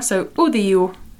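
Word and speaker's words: àudio: It's similar to Spanish, audio, but the O's sound like U's here. So audio.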